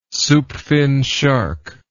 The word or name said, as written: soupfin shark